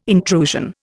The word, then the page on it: intrusion: Indian